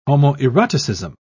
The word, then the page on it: homoeroticism: British English